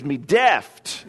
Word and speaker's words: deft: It means daft, deft is the way that's pronounced.